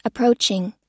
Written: approaching

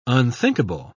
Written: unthinkable

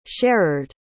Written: sherard

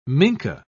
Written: minke

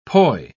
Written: poi

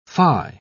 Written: phi

